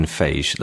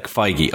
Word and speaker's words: Feige: Now, you used the word overseer about this guy, Kevin Feige. Now, I as a layperson... – Feige. – Okay, see.